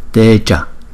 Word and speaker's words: teja: Còn chữ trước chúng ta đọc là teja, teja. Chữ A nó không có dấu gạch ngang trên đầu, chúng ta đọc là a, a, a, teja, teja.